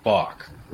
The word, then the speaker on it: Bacque: Well, you know, it is spelled B-A-C-Q-U-E. So, I mean, I've heard just about every, interpretation of this under the sun, but I've been told since I was a boy, it's back, like front to back.